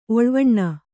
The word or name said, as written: ಒಳ್ವನೆ